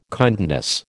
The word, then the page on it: kindness: American English